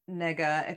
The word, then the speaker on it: Negga: And Ruth Negga, except I think the pronunciation of her last name is actually like Negga, because I was looking at her Wikipedia page and that's what the pronunciation said on there.